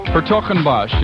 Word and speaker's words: Hertogenbosch: I think it's Verbeesen, though, of Hertogenbosch in the Netherlands. Hertogenbosch, yes, that's how you pronounce it.